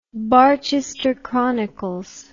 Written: barchester chronicles

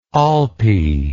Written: olpe

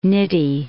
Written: Nitti

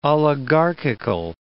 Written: oligarchical

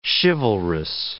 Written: chivalrous

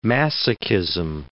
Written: masochism

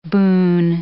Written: boon

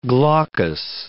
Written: glaucous

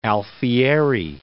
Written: Alfieri

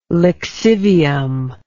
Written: lixivium

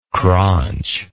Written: craunch

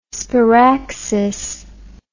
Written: sparaxis

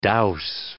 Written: dowse

